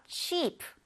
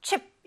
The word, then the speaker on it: cheap: cheap, cheap. cheap. 여기에서 chip 이렇게 너무 짧게 발음을 하게 되면 우리 감자칩 얘기할 때 그 칩이 되니까 cheap 이렇게 길게 발음을 해주세요.